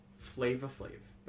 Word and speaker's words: Flavor Flav: and rapper Flavor Flav. – I think it's pronounced Flava Flav, but yeah.